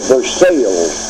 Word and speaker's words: Versailles: V-E-R-S-A-I-L-L-E-S. Some prefer the English pronunciation, the Treaty of Versailles.